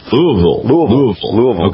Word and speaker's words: Louisville: Tell Don it's pronounced Louisville. – Louisville.